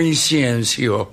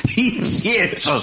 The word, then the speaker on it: incienso: ¿Incienso o inciencio? – No, incienso, señor.